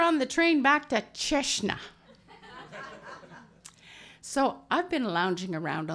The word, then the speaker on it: Cecina: Now I'm going to try my best stab at the pronunciation of C-E-C-I-N-A. Cecina. Turns out that word has two totally different meanings. Depending on which syllable you put the accent on.